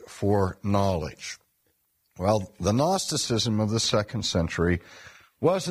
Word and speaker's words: Gnosticism: and that was the movement called Gnosticism. Now, when we spell Gnosticism, we spell it with a G, G-N-O-S-T-I-C-I-S-M. And Gnosticism comes from the Greek word Gnosis, which is also spelled with a silent G, G-N-O-S-I-S, which is simply the Greek word for knowledge.